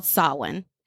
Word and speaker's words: Samhain: So it's written as spelled as like Samhain, Samhain. But when I Google the pronunciation, it's actually called Samhain.